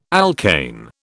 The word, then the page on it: alkane: British English